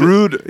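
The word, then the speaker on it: Rohde: Like, is it Road or is it like Road? – Rude. Yeah.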